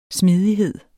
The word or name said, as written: smidighed